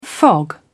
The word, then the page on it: fog: British English